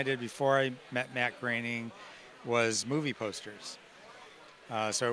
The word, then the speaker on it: Groening: Groening. – Groening. I just want to make sure I got his name right. – He always says his name rhymes with complaining.